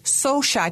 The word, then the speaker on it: Xocai: You said so shy chocolate.